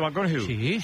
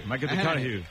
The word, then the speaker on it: McConaughey: Mateo McConaughey. – Sí. – Me fichó, le gusta. Que hay que ir corrigiendo la pronunciación. – McConaughey.